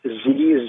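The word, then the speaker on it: xe: Well, it's spelled X-E, X-E-M, and X-Y-R, and it's pronounced Z, Zem, and Zir.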